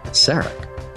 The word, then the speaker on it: Sarek: No, Ken, it's Sarek.